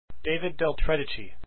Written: del tredici

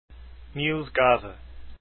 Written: gade

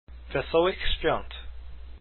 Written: Wesolych swiat

